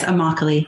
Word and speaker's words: Immokalee: People of Immokalee. Did I pronounce that right? – Yes, Immokalee.